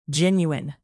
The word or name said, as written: genuine